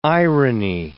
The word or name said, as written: irony